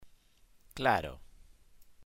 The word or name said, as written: claro